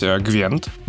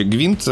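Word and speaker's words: Gwent: Во-первых, компания а, продолжает развивать Гвент, Гвинт, я почему-то английское слово сказал. Короче, Гвинт э, продолжает развиваться как отдельная...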